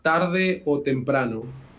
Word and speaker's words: Tarde o Temprano: How do we pronounce it? – It's called Tarde o Temprano.